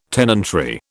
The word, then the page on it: tenantry: British English